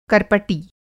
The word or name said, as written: कर्पटी